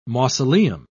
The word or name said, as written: mausoleum